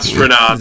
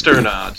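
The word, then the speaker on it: Strnad: It's not Sternod, though. Right? It's Sternod, so I'm glad you didn't pronounce that's what I said.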